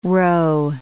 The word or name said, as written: row